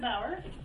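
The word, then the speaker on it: Bower: Hello, my name is Teresa Bauer, and I am with the U.S.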